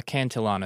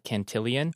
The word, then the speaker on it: Cantillon: I've actually mispronounced that for years. I used to call it Cantillion. – That's what I thought it was. – But I think it's actually spelled Cantillon.